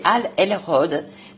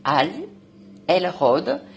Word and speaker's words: Hal Elrod: C'est Al Elrod. Ça s'écrit H-A-L-E-L-R-O-D. Al Elrod.